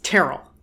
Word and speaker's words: Terrell: I have always pronounced it Mary Church Terrell, but it is Terrell. Her and her husband, it's not an uncommon name. Different people pronounce it different ways, but her and her husband pronounced it Terrell.